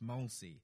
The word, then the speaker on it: Molesey: Molesey, he's just told me. It's pronounced Molesey.